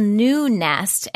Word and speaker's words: NuuNest: It's called New Nest, and it's spelled N-U-U-N-E-S-T. And you do have to pay for it.